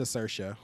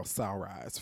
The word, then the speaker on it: Saoirse: And to see that unfold when it unfolds, it's a credit not only to Saoirse, whose name I learned how to pronounce 2 weeks ago. – You learned. That's what it takes. – I was calling that little girl Sunrise for so long.